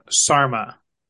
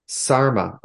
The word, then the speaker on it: Sarma: I knew I was saying it wrong. Sarma. – Sarma. Okay. – Yes. – S-A-R-M-A.